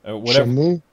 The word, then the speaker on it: Shenmue: Shamu, or am I saying that right? Shamu 3? – Shenmue? – Yeah, one Shenmue.